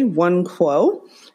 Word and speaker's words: Nwankwo: And yes, you did pronounce the last name correctly, Nwankwo.